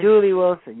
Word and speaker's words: Dooley Wilson: Is it Dooley Wilson? Am I saying that right? – Dooley Wilson.